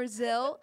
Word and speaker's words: Zil: I walk up and I go, Zill Nyer, that's, spell it. – Z-I-L for Zill. – And Z-I-L? – Then, yeah, and then Nyer is N-I-R-E.